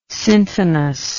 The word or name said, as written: syntonous